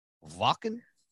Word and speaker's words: Wacken: I mean, you've played, you played Wacken? – Wacken. Das ist Wacken. – That's how you say it.